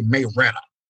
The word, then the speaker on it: Marietta: Now, we don't say it like that. We say Marietta.